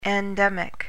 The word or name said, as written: endemic